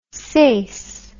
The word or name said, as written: seis